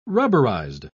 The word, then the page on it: rubberized: British English